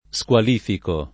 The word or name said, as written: squalificare